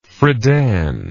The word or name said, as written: Friedan